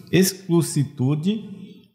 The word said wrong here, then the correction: excelsitude: Olha, só para falar que é errado. Falei errado. Sentes a excelsitude do teu próprio destino.